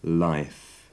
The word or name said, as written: life